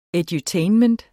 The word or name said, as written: edutainment